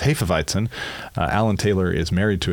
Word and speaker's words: Hefeweizen: They both said, no, there's no schwa, which was part of the correction. And it's Hefeweizen is a totally fine pronunciation. Alan said, if you want to get into the really deep weeds, that second E, Hefe, is pronounced like the E in less, as opposed to Hefe.